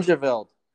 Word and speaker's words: Hengeveld: Hengeveld. – Hengeveld. – Okay. Yeah. There's a lot of, you know, pronunciations. Some people pronounce it with an F, and I don't know where that comes from, but that's why everyone just calls me Henge.